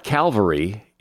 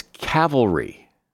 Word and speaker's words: cavalry: It's not Calvary. It's Cavalry.